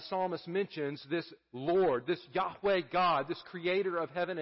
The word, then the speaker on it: Yahweh: We would pronounce those Hebrew letters Yahweh.